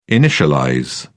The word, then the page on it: initialize: British English